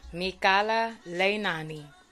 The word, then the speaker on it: Mikala Leinani: Hey everyone, welcome back to I Like It Raw podcast with your girl Mikala Leinani, or MK if you cannot pronounce my name, but let's just take some time to say my name slowly. Mikala, Mikala Leinani, Mikala Leinani.